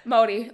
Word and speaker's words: Māori: Like basically, Māori. Yeah, like it's kind of like, I don't know how to describe the middle section, like kind of rolls it a little bit. – You roll the R a little bit.